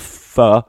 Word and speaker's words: pho: Well, hers was a little different because it said get a bowl of pho and add sambal. – I'm glad you're. You're pronouncing it correctly now. – Yeah. – At first you were like faux skin, yeah. Eat a bowl of faux skin, yeah.